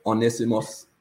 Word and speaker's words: Onesimus: You said, had I known about one, and I hope I don't know how to pronounce this, Mikey, Onesimus, how would you pronounce that name? – Yeah, that's correct, Onesimus.